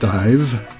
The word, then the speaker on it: Daimh: It's pronounced Dive, D-A-I-M-H, Dive, in Scots Gaelic.